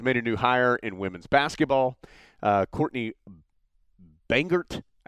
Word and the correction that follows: Banghart: I think that's the right way to pronounce it. – Hi, guys. I'm going to stop the pod right there for just a second for a couple pieces of information. Number one. Since Adam and I have recorded this pod, I had the opportunity to meet Coach Banghart. That is the right way to pronounce her last name.